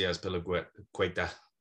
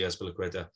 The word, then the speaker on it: Azpilicueta: I see Azpilicueta, that's my best Spanish pronunciation. I do see Azpilicueta getting that contract, that one-year extension.